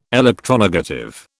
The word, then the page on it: electronegative: British English